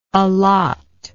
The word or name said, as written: allotter